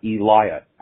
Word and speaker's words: Elijah: Hey, this is Elijah. I'm the person that called in yesterday with the Becky Lynch, Roman Reigns, Seth Rollins storyline. Sorry, last week. My name is pronounced Elijah. I'm not a Wyatt.